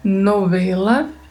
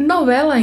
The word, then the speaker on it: novela: Essa letra V tem um leve som de B. Então falamos novela, novela. E novela em espanhol significa uma obra literária em prosa, onde se narra fatos fictícios, né? – É um gênero literário.